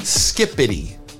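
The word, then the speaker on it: skibidi: It's pronounced Skippity. Because it's spelled with a B. – Yeah. – A B and a D, but it's pronounced with a P and a T.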